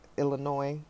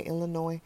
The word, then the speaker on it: Illinois: It's Illinois. Sorry, not Illinois. I know my other Illinoisans are like, is this, you don't pronounce the S. Okay, Illinois.